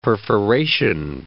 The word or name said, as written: perforation